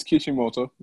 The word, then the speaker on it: Kishimoto: Mishimoto. No, it's in Kishimoto. Kishimoto. I said his name wrong. I know I did. – It's Kishimoto. Yep.